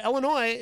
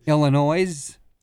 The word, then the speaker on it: Illinois: Illinois is a great place. I spent a lot of time there when I was a kid. – Illinois? – No, the S is silent, my friend.